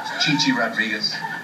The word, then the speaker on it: Chi Chi Rodriguez: Winner of this week's Gulf Coast Golf Classic was Chai Chai Rodriguez. Chai Chai finished with 9 under par score. – Chi Chi Rodriguez.